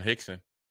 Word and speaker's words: Hickson: You got John Hickson. Rhymes with Dixon.